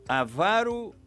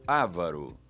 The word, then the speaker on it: avaro: Como você pronuncia filantropo ou filântropo? Ibero ou íbero? Avaro ou ávaro? Todas essas palavras são paroxítonas e assim devem ser pronunciadas filantropo, ibero, avaro.